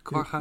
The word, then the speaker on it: quagga: Ja, want dat is wel die Quagga inderdaad, of zeg ik dat goed? – Quagga? Eh, nee, nou, iemand die heeft er onderzoek naar gedaan, die heeft tegen mij gezegd dat je Quagga moet zeggen, dus dat zeg ik nu ja.